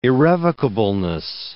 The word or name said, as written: irrevocableness